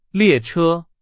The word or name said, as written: 列车